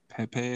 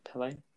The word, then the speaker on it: Pele: Pepe, I think his name is. – Pele.